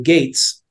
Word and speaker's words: Gates: And I said, well, I guess from now on, you're going to pronounce my last name Gates.